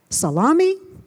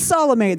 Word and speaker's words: Salome: It's a million and one different ways to pronounce it. Salome, salami, sounds like the lunch meat. And I went with Salome this morning.